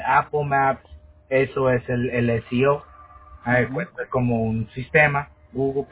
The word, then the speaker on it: SEO: Todos los que me han hablado de esto, el SEO, ¿es cómo? – SEO.